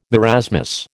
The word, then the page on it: marasmus: American English